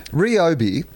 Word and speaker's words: Ryobi: Ryobi is how it's pronounced.